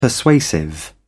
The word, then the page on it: persuasive: British English